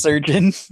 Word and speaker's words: chirurgeon: Which is pronounced surgeon, despite it looking much different.